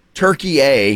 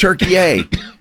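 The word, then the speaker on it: Türkiye: Turkey A is how it's always pronounced. And if you'll notice, they don't spell it turkey like... – Gobble, like what? – Like gobble, gobble. – Jesus. So yeah, don't do that again, but do that again. – So they spell it differently, and it's pronounced Turkey A.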